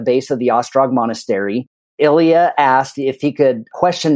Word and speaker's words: Ilija: First one that picked up the phone was a gentleman named Ilija Mušković, and his name is spelled I-L-I-J-A, which ironically looks a little bit like Elijah, but it is Ilija.